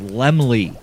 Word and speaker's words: Laemmle: I have always called him Carl Lemmel Jr. That is not how his name is pronounced. His name is pronounced Lemley.